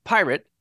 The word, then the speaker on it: PYRT: His new intelligence-free initiative called PYRT, all capital letters, and is supposed to be pronounced pirate because Billy is awful.